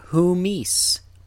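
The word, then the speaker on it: humis (ὑμεῖς): See the hard breath accent on the beginning letter there, humis.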